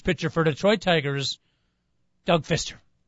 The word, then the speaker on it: Fister: By the way, it is Fister without the silent P.